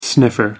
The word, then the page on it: sniffer: American English